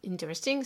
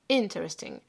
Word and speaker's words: interesting: En fait, c'est pas interesting, c'est interesting. Il faut vraiment insister sur cette première syllabe.